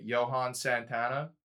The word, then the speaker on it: Johan Santana: Johan Santana.